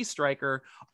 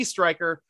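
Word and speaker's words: Stryker: Follow the Steeler Nation podcast on Twitter at _SN podcast, or follow your lowly host, G Striker, on Twitter and Instagram at SN Striker, Striker spelled with a Y.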